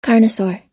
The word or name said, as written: carnosaur